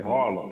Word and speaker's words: Harlem: I say Harlem. H-A-R-L-E-M.